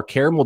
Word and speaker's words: caramel: And somebody messaged me on Twitter and said that I'm saying it wrong with the new flavor of Built Bar, caramel brownie. I say caramel. Guys, caramel, caramel? How do we say it? – Caramel. The second A is questionable. – Okay, I'm going to skip that second A.